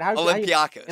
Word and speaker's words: Olympiacos: How do you say it? – Olympiacos. – Olympiacos.